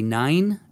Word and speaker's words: nein: And in the second season, they were called the Mighty Nine. And nine is like the German N-E-I-N or however you pronounce that, right? Nine? Like nine? – No. Nine? Like no.